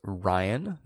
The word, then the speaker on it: Ryan: That's spelled R-Y-A-N, not with a B, as I frequently get called at Starbucks, but with an R. – How would you spell Ryan with a B? – Well, no, they think I'm saying Brian. I don't know if it's just the way that I say Ryan, but it's often the case that when I say my name out loud, people go Brian.